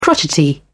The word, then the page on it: crotchety: British English